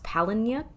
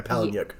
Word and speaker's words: Palahniuk: I believe it's pronounced Palahniuk. – Yeah, Palahniuk.